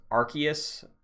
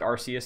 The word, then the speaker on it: Arceus: Excited to play the remakes, but more importantly, uh, they announced Pokemon Legends Arceus. I pronounced it Arceus since I was 13, but apparently it's Arceus.